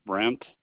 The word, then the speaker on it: Brent: My first name is Brent, B-R-E-N-T, and my last name is Spaulding.